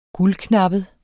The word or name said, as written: guldknappet